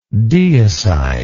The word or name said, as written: deicidal